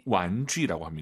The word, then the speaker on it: 玩具: 장난감을 중국어로 玩具。 – 완쥐라고 합니다. 제이 유 발음 잘하세요? 주 아니고 쥐입니다, 여러분. 완쥐.